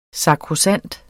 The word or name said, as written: sakrosankt